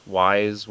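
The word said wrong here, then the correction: Ys: 1 and 2? – Ease. – Ease, whatever. – Ease 1 and 2.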